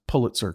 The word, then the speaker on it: Pulitzer: Notice I pronounced Pulitzer correctly. Yes. That's how David Lindsay Abert told me I should be pronouncing it.